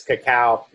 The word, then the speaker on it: cacao: The real name of chocolate is cacao. It's not cocoa.